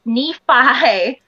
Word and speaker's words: Nephi: And I was just like, no, honey, it's Nephi. If you're going to take names from something, look at the pronunciation.